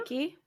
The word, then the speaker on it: Reiki: So after the course, she was able to start her own business as, I'm going to say this wrong, as a Reiki practitioner. – A Reiki, yeah.